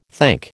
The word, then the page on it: thank: American English